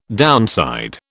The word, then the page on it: downside: British English